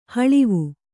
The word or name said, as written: ಹೊಳವು